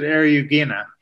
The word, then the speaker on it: Eriugena: How do I say that? – Well, I pronounce it Eriugena.